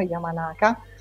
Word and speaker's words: Yamanaka: Quello che invece si fa in questo caso, Yamanaka, o non so la pronuncia esatta, è giusto.